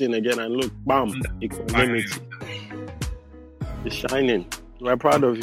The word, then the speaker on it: Equanimity: Equanimity.